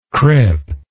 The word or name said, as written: crip